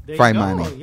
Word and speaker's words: Phaimani: Phaimani.